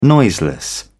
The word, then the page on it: noiseless: British English